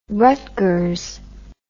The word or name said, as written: Rutgers